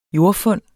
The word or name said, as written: jordfund